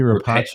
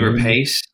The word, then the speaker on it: Rapace: Noomi Rapace. Noomi Rapace.